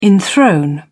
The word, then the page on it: enthrone: British English